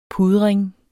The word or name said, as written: pudring